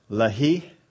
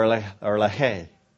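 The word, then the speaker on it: Lehi: Now this is pronounced a couple of ways. Either Lahi or Lahay.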